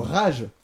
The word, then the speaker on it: Rage: Comment on prononce Rage Against the Machine? – Rock Against the Machine. – Non, Rage. – Ah oui, Rage.